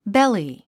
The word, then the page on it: belly: béli; ベリィ